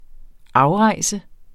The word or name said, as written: afrejse